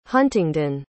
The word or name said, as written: huntingden